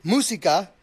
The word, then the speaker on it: Musica: I go by Musica, which is music in Spanish. Most people just call me Music because they cannot pronounce it correctly.